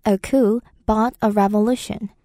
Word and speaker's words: coup: 영어에서는 주로 줄여서 앞에만 쿠라고 표현을 하는데요. 스펠링은 C O U P라서 마치 쿱처럼 보이지만 발음할 때 P 사운드가 안 나고요. 쿠라고 발음합니다.